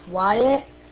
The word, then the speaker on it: Wyatt: Roger, my name is Wyatt, spelled Whiskey, Yankee, Alpha, Tango, Tango.